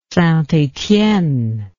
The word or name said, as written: St Etienne